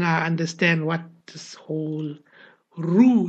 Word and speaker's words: roux: Roux is pronounced as roux,